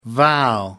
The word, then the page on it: vow: American English